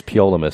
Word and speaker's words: Ptolemy: The T is silent. It's Ptolemy for me now.